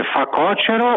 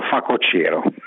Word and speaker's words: facocero: Poi c'è una cosa che sono anni che non riesco a pronunciare bene, si dice facocero o facocero? – Questa è una domanda interessante perché io credo che tutti dicano facocero, ma in realtà si dica facocero.